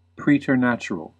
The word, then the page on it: preternatural: American English